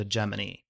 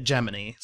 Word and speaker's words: Gemini: Amy pronounces it Gemini, so maybe I need to just change over and say Gemini.